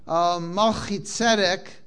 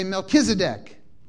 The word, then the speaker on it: Melchizedek: But in English, people, instead of Shekhinah, they say Shekinah. Instead of Malchitzedek, my righteous king, they say Melchizedek. Because people don't know how to pronounce Hebrew, so they butcher it.